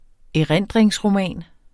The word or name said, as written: erindringsroman